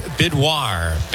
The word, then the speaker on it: Bidois: And the last name is Bidwar. Bidwar. Bidwar takes over. Brandon Bidwar. And he'll face Gage Workman. Bidwar, by the way, is spelled B-I-D-O-I-S.